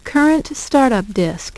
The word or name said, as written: current startup disk